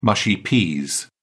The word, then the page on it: mushy peas: British English